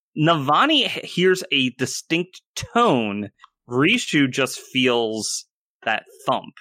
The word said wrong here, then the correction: Rushu: Rushu. – Oh, R U S H U. I don't know where you get these pronunciations.